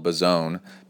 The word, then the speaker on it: Bozzone: All right, the biggest news story going on right now is at the beginning of December, pro triathlete Torenzo Bazzone, and it's spelled Bazzone, B-O-Z-Z-O-N-E, but if you're in the know, you say Bazzone.